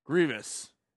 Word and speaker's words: Grevioux: Grevioux. – Grievous. – Sure. He worked on Underworld and Frankenstein. – Okay. – So Grevioux.